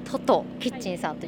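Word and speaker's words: 1010キッチン: インスタグラムですね、あとはフェイスブックもやってます、はい、トトキッチンさん 1010 と書いてトトキッチンさんと読みますね、はい、ちなみにこの名前の由来はどこから来てるんですか？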